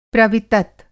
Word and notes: प्रवितत: Hindi

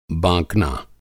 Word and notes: बाँकना: Hindi

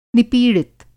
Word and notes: निपीड़ित: Hindi

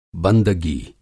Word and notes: बंदगी: بندگی

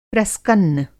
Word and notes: प्रस्कन्न: Hindi

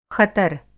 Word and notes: ख़तर: Hindi

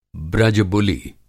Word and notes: ब्रज-बुलि: Hindi

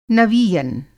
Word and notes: नवीयन: Hindi

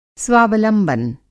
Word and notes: स्वावलंबन: Hindi

